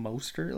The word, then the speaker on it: Mostert: I got Mossert, whatever his name is, from San Francisco. – You going to disrespect Raheem Mostert like that? – Mostert? – Yeah. – Like Mustard? – He's been saying his name wrong the whole time.